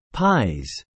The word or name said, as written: PIES